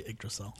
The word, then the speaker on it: Yggdrasil: I want to see Thor traveling Yggdrasil. I literally said that sentence because I wanted to say Yggdrasil.